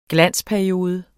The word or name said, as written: GLANSPERIODE